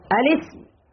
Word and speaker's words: الاسم: همزة الوصل لا تنطق في حال الوصل، فمن الخطأ أن نقول إيش؟ الاسم، فهمزة همزة وصل، إذن كيف ننطق بها؟ الاسم والفعل والحرف. أما الاسم، هذا خطأ أم صواب؟ خطأ. أنا أريدكم أنظر هل تنتبهون أم لا. أما الاسم خطأ، إيش الصواب؟ أما الاسم، أما الاسم همزة وصل لا تنطق، تنبهوا لها، وهذا الخطأ يقع فيه كبار الإخوة، بل بعض المشايخ يثبتون همزة الوصل، وهي همزة وصل ما ضابطها؟ تثبت في الابتداء وتسقط في الوصل أو في درج الكلام.